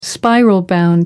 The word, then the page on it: spiral-bound: American English